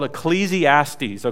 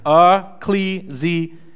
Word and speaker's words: Ecclesiastes: So this is how it's pronounced. Ecclesiastes, okay? I had to be careful with this one, all right? So let's all say it together. Ready? Ecclesiastes, all right? One more time. Ecclesiastes, all right? That's how you pronounce it.